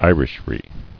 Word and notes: Irishry: I·rish·ry; I"rish*ry

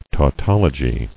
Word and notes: Tautologous: tau·tol·o·gy

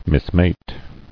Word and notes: Mismate: mis·mate

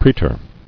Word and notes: Praetor: prae·tor

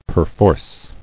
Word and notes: Perforce: per·force

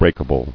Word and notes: Breakable: break·a·ble

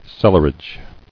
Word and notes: Cellarage: cel·lar·age

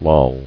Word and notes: Lollingly: Loll"ing*ly

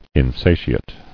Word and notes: Insatiately: In*sa"ti*ate*ly

